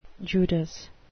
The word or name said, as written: judas